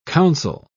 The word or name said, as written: counsel